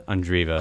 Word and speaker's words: Andreeva: How do you say Mira's last name? Andreeva? – Andreeva. – Andreeva? – Yeah.